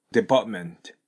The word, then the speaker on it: department: そして、デパートメントの T なんですが、 T の前に N があると、 T を発音されないことがよくあります。 department。これ T を発音しないことで、 is とつながると、 department is というになります。